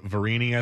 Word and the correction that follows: Sirianni: I think his name is, or Variani. I forget how you say his name. Because, uh, or, or, uh, Sirianni, not Variani. Uh, Nick Sirianni is his name.